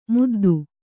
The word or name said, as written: ಮಿದ್ದು